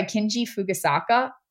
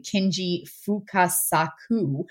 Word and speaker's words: Kinji Fukasaku: This is directed by Kinji Fukasaku. Or sorry, I said that incorrectly. Kinji Fukasaku, who...